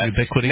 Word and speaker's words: Ubiquiti: I don't know how to pronounce that. – Ubiquiti Networks.